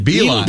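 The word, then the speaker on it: Beilein: John Beeline.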